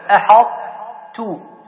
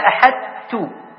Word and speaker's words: أحطت: تقول أحطت، لا تقول أحط ولا أحدت، فتنطق أصل الطاء.